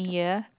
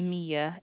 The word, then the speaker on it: Amia: Amiya, A-M-I-A. – What is it? Spell it again. – Amiya, A-M-I-A.